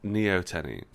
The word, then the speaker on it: Neoteny: Well, he's asking for Neoteny. Is that how it's pronounced? – Neoteny, which is that's what he's asked for. He spelt it Neo-Tony. – And it's not spelt that way.